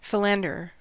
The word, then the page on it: philanderer: American English